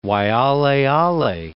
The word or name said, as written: Waialeale, Mount